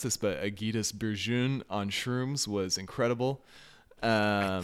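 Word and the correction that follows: Ágætis byrjun: Algaitis Beirun. Algaitis. – Yeah, that makes sense. The J should probably be pronounced like a Y.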